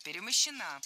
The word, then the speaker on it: перемощена: Перемощена – это неверно. Правильный вариант – перемощена. Ударение в этой форме падает на окончание «а» – перемощена.